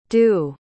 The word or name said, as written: doux